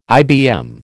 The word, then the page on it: IBM: American English